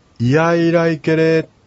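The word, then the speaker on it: イヤイライケレ: はい、それでは例文の 1 番目をちょっと私が 2度ほど一人で発音しますので、これはありがとうというアイヌ語なんですけども、いやいらいけれというふうに言います。いやいらいけれ、まあ、この発音の仕方なんですけども、このやいというところをはっきり発音するというのが実はポイントなんですね。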